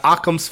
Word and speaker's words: Occam's: So tell me about OCAMS Fitness. What is that? What does that mean? Am I even saying it right? – No, but it's okay. it's Occam's Fitness.